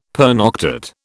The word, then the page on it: pernoctate: British English